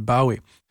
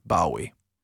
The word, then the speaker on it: Bowie: Men uttalet ändrades från Bowie till Bowie.